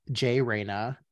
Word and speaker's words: Jay Reyna: You can find me on Instagram at not Jay Reyna, and my name is spelled J-A-Y-R-E-Y-N-A.